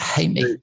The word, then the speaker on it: Jaime: Spaniard, Ivan Jaime, I think you pronounce it Jaime, Ivan Jaime, if you were pronouncing it in English way.